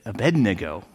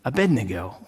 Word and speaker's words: Abednego: Here, of course, Daniel's not the focus, but his friends, Shadrach, Meshach, and Abednego. Why can't I say his name? Abednego. Wow.